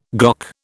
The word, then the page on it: GOC: British English